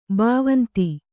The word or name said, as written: ಭವಂತಿ